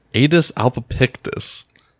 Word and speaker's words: Aedes albopictus: Aedes albopictus.